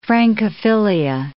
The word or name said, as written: Francophilia